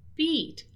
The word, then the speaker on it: beat: So the pronunciation of these two words here, B-E-A-T and B-E-E-T, they are very, very similar. Beat, they have a long E sound. So it's something like beat, right? Beat, beat, beat. While the word that we are talking about here today in our dialogue is bit, bit, a little bit, a small bit, a bit of time, wait a bit, okay?